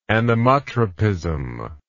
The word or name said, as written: anemotropism